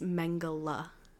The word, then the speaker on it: Mengele: So she was called Dr. My mom said I said it wrong. So I think it's Mengele.